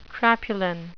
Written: crapulent

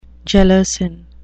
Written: gelasin

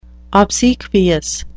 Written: obsequious

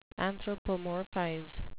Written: anthropomorphize

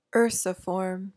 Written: ursiform

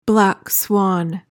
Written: black swan

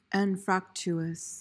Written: anfractuous

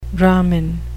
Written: brahmin

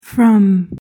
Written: frum

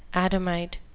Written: adamite

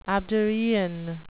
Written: abderian

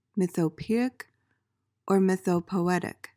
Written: mythopoeic

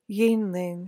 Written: yeanling

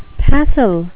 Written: passel

